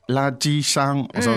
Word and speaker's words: 垃圾箱: 垃圾箱。So it's three first tones there by the sound of it.